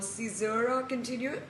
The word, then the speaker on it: Caesura Continua: So your first, one of your first solo exhibitions was called Caesura Continua. Am I pronouncing it correctly? – Yes.